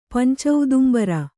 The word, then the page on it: ಪಂಚೌದುಂಬರ: pancaudumbara